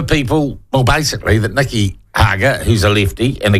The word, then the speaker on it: Hager: Dirty Work, the latest book by, and I'll use inverted commas, investigative journalist Nikki Hager, rhymes with lager, and the subheading of the book, Dirty Work, the Taxpayers Union and New Zealand's Hard Right.